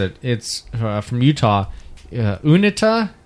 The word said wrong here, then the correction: Uinta: Winta. – Unit. – Winta. – Winta. Oh, Winta. It's like a Native American pronunciation.